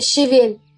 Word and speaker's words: щавель: Ударение в слове щавель ставят где на первый слог, а где и на второй, хотя правильным в целом считается ударение на «е».